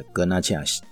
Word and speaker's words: Garnacha: 这个格纳恰是 G A R N A C H A，那。本来应该是要先念格纳西啊，格纳西是 G R E N A C H E 啊，那这个是最常见的。那因为西班牙的话会有刚刚讲的格纳恰